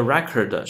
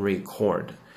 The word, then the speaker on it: record: For the record，首先读音哈，千万不要读成 record。如果读成 record 是动词，表示记录、录音等等，这个相信大家都已经有印象，都记得住。